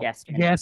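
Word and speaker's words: Gaskin: Myles Gaskins. – No. Gaskins. There's no S at the end.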